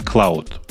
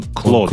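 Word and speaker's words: Claude: Чуваки, он не клауд, он клод. Он клод. – Да, Клод Айл, Cloud, да. – Но ну, он пишется cloud.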